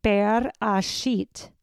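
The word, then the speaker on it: Bereshit (בראשית): The word for in the beginning is pronounced Bereshit.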